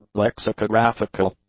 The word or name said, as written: lexicographical